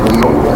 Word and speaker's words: Weil: W-E-I-L is French, so you don't pronounce it Weil, it's Weil.